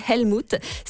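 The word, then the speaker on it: Älmhult: Ça s'écrit Helmut, mais ça se dit Helmut.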